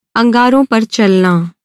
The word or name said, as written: अंगार